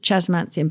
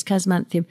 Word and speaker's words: Chasmanthium: And I'm totally mad keen on Chasmanthium latifolium, and that's Chas, C-H-A-S. But sometimes people pronounce it Chasmanthium, but I think correctly it's Chasmanthium.